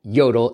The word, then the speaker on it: Jodel: He is the product manager of growth at Yodel.